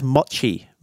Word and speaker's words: mochi: Let's talk about injuries, and let's talk about I think it's pronounced mochi. Mochi? Mochi. The Japanese dessert.